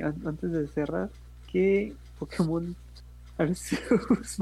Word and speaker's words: Arceus: El nombre, la forma correcta de pronunciarlo es Arceus.